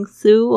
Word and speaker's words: through: You've been through a lot. Through 特别注意一下它的发音，th 这个啊，两排牙齿轻轻咬住这个舌尖，发出这样的轻 through，然后加上这个 r 的声音 through through gh 这边是没有发音的哦。